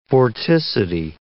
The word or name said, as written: vorticity